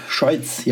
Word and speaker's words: Scheutz: Schauce Schojc, možná takhle se vyslovuje. – Schojc, já, já, jo, e, Schojc.